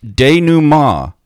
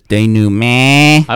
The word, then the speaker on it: denouement: It's pronounced denouement, but that last syllable is nasalized. So I guess it's denouement.